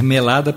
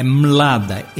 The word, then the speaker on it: Mlada: Não pode ser melada porque ficaria feio. É Mlada, M-L-A-D-A, do qual também foi depois tirada uma suíte.